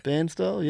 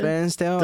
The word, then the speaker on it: Bairnsdale: Down towards Bairnsdale, whatever yep. the hell. I don't even know how you say it. How do you say it? – Bairnsdale, yeah. – Bairnsdale.